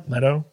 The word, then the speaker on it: Leto: And so everything with Jared Leto or Leto? – Leto.